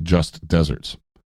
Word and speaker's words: just deserts: Now, when you say just desserts, we pronounce it dessert, which is what you eat after dinner. – Yes. – And it's spelled D-E-S-S-E-R-T-S. But just desserts is actually technically pronounced just deserts, because it's spelled like desert. You know the place with a lot of sand? – You mean Antarctica? – It is a desert. You are correct. You're so smart. But it's spelled D-E-S-E-R-T. S. No double S.